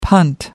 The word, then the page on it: punt: American English